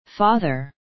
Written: father

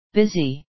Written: busy

